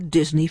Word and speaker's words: Disigny: The book's title, as we've said, is The Other Disney, D-I-S-N-E-Y. And all along, we meet the Disney family, D-I-S-I-G-N-Y, pronounced Disney too.